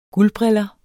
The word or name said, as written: guldbriller